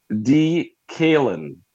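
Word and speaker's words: Dekaylin: Well, what this guy's mama did name him, DK Metcalf, is D-Kalen.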